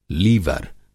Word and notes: लीवर: Hindi

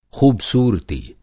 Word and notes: ख़ूबसूरती: Hindi

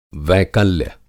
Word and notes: वैकल्य: Hindi

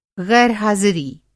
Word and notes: ग़ैर-हाज़िरी: غیر حاضری

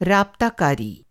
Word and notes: राब्ताकारी: رابطہ کاری; Hindi

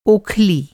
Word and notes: ओखली: اوکھلی

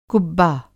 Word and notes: कुब्बा: Hindi

